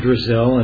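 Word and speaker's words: Zdrazil: Yes, my name is, Mr. Chairman, members of the committee, my name is Al Drazil, and for the record, I'll even spell it for you. It's Z-D-R-A-Z-I-L, so it's spelled nothing like it sounds, which makes it additionally difficult.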